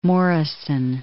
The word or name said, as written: Morrison